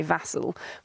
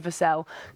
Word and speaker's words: Vassell: So my father's parents are Jamaican and they'd say Vassell. But there was a footballer who played for a local football team when I was growing up and then played for England and everyone pronounced it Vassell. So my family have all gone, right, well, we're going to some posher. We're going to say Vassell and okay.